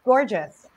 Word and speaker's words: PORJS: And I was like, how do you say that? And then it dawned on me, gorgeous.